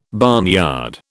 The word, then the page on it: barnyard: British English